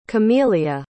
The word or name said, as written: camelia